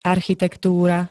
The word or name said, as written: architektúra